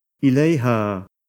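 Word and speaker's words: إليها: D'autre part, lorsque la particule du cas indirect termine par la lettre alif et qu'un pronom personnel lui est rattaché, la lettre alif se transforme en ya pour faciliter la prononciation. On doit donc bien attacher les deux mots à l'écrit et prononcer ilayha.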